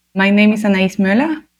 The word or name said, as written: Anais Möller